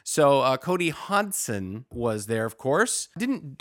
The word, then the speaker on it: Hodgson: One for sure named Cody Hodgson. You don't pronounce the G, my friend.